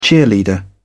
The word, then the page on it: cheerleader: British English